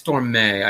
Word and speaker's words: Stormé: Johnson, and is it Stormé or just Storm? – I believe it's Stormé, I believe.